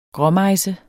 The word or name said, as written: gråmejse